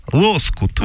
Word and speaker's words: лоскут: Это не лоскут, а лоскут. – Угу. – На первый слог какого-то черта ударение ставится. Если же эти обрезки предполагается использовать в качестве заплатки, в качестве там одеяла, тогда это лоскут.